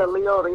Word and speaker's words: Liotta: Is that how you say his name? – Who? – You know Ray Liotta. – Yeah, I like it. Yeah, Liotta, yeah.